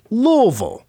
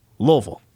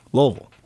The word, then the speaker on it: Louisville: She's like, no, say less. You're doing too much. It's not that strong of a pronunciation. Nice. So it's like Louisville. That's it. Louisville. That's the way they like it to be pronounced, but nobody does.